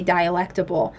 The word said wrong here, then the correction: dialectical: Can you help me out, Grace? – Oh, I mean, you're not terribly off, just dialectical.